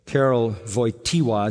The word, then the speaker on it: Karol Wojtyła: After the death of his mother, when he was eight years old, Karol Wojtyła is how you say his name, the pope that died.